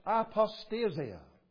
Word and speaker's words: apostasia: But the original word is pronounced something like this, apostasia.